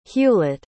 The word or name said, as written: hewlitt